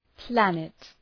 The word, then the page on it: planet: ‘plænıt